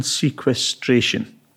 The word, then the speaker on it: sequestration: So carbon sequestration. One question, have I said it right? And two, What is it? – Sequestration, yes, that's fine. That's a fair enough pronunciation.